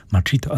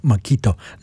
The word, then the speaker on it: Machito: A w chwilę później Machito and his famous orchestra, Machito albo Machito, zależnie czy z pozycji hiszpańskiej, czy brytyjskiej, czy angielskiej, może lepiej powiedzieć, amerykańskiej.